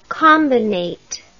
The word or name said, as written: combinate